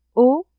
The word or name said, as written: haut